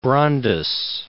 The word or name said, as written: Brandes